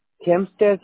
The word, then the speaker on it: Hempstead: Hampstead? – Hempstead se escribe...